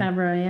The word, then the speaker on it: Favreau: Favreau, yeah.